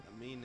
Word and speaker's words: Amine: I was listening to that anime album. It wasn't too bad. – Amine, man. Amine. – How you pronounce it? It's not anime? – Amine. M. It's A-M-I-N-E. Amine.